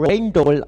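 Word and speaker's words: Rijndael: Also ihr könnt ihn insbesondere Raindoll aussprechen, was mir relativ gut gefällt.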